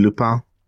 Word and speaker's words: Lupin: They pronounce it Lupin. – Okay. – You know, because they're French.